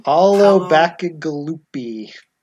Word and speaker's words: Paolo Bacigalupi: Paolo Bacigalupi.